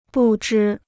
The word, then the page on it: 不知: bù zhī